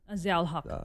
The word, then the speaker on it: Zia-ul-Haq: Zia-ul-Haq.